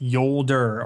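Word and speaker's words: Jolder: Yes, it is pronounced Yolder, or at least all the writers pronounced it Yolder.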